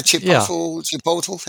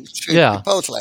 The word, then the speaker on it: Chipotle: The Chipotle? Is that how you pronounce it? – Oh, Chipotle? – Chipotle, yeah.